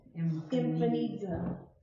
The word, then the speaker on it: emphanizo: E-M-P-H-A-N-I-Z-O. – Mm-hmm. – But the way you say it phonetically is E-M-P. Yeah. – Infinizo.